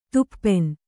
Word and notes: ತುಪ್ಪೆನ್: tuppen

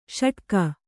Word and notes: ಷಡಕಿ: Kannada

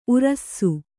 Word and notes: ಊರಾಸೆ: Kannada

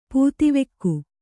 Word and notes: ಪುದುವೋಗು: puduvogu

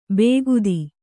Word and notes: ಬೇಗುದಿ: Kannada

